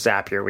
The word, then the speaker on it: Zapier: And yes, it is Zapier. We get into that in this episode, as how you pronounce that service, as well as a whole bunch of other automated related information and discussion points.